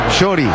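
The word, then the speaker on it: Chorý: Chori. Acento en la con Y al final.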